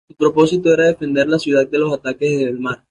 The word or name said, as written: defender